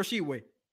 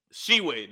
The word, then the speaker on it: Tshiebwe: Oscar Tshiebwe. – Tshiebwe, that's how you say his last name.